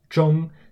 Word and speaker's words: zhong: A good word that uses the ZH initial is zhong, spelled Z-H-O-N-G in the first tone, which means middle or center.